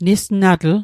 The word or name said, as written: nisnatl